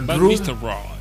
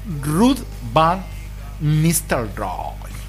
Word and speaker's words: Ruud van Nistelrooy: El Ruud van Nistelrooy. – Sí, porque estamos... Este es holandés, pero es en Inglaterra. Entonces le damos un tinte inglés. Ruud van Nistelrooy.